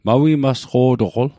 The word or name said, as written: mawimasqo'toql